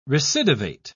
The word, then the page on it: recidivate: British English